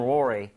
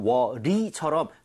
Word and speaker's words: worry: 큰 건 아니지만 이것도 발음할 때 너무 worry처럼, real처럼 발음하지 마시고요. 아시죠? 입 모양 모으고 혀 코어 부분을 당긴다라는 느낌. So worry, re라고 돼야지 re 이렇게 하시면 안 됩니다.